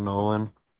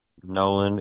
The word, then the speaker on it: Noland: Joshua Nolan or Josh Nolan, either or. – Okay, and last name, how do I spell that? – Nolan, NOLAND.